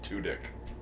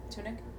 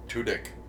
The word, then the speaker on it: Tudyk: Just so you know, his last name is totally pronounced Tudyk. – Tunic? – Tudyk.